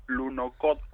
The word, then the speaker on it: Lunokhod: Lunokhod 1, como se pronuncia en ruso, claro, que aterrizó pues allí en la Luna en noviembre de 1970, casi nada.